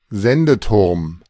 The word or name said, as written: Sendeturm